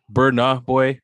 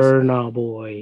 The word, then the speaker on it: Burna Boy: I don't know how to pronounce it, but Burner Boy, Burna Boy. I apologize. – Burna Boy.